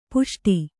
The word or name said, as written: ಪುಷ್ಟ